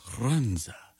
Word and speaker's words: Runza: Runza. You have to roll your R's. Runza.